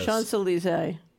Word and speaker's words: Champs-Élysées: Is how you pronounce that? In Paris. – Champs-Élysées.